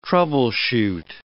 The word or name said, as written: troubleshoot